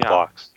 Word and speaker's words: Bloch: So many blocks. – And there's it's spelled differently as well. Bloch for Robert Block. It's Block for Lawrence Block.